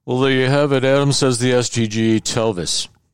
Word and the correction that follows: Tellis: Rhymes. Tellis. T-E-L-L-I-S. Oh, I was having it rhyme with Elvis.